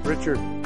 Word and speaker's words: Richard: B-E-V-E-R-L-Y. – And what's your name? – Richard. – Richard, can you spell that? – R-I-C-H-A-R-D.